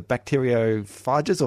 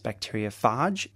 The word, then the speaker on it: bacteriophage: Outside of America, it's bacteriophage. In America, it's bacteriophage. I kind of wish it was bacteriophage because it rhymes better with certain things. But bacteriophage, it's thought that we should say it as it's singular and plural, not bacteriophages.